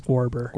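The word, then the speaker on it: Guerber: I'm pretty sure it's Gwerber. – Gwerber? – Yeah. – All right. – Like a Gerber, but with a U in it. Gwerber.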